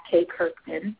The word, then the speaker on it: Kay Kirkman: So it's just at Kay Kirkman, my name.